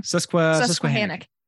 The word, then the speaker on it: Susquehannock: Susquehannock. – Right. – There we go. – There you go. Susquehannock.